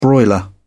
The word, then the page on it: broiler: British English